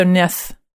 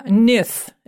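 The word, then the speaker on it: níð: There's a thorn in there. You have to say it with a thorn. – So how do you say it? I forgot how to say a thorn. – Thorn. – The. Okay, so Nith. North. Yeah, Nith. It's N-I-S-H. Nith.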